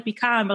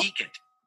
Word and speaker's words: piquant: Oh, that's funny because I immediately thought it sounded like pecan, but like a fancy way to say it. – Mm-hmm. So I just want to make sure I said it right. I did. So I said it right. Let me see. Oh. Piquant. Piquant. Okay. So it's piquant.